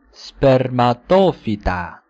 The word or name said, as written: spermatophyta